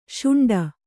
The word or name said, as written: ಶುಂಠಿ